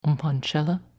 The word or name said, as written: Umpanchela